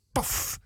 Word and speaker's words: PAFF: En in onze filmrubriek, later in dit uur, zullen we degelijke aandacht besteden aan de PAFF. Uitspreken als PAF. Ik vind het verschrikkelijk om het te moeten zeggen, maar het heet nu eenmaal PAF.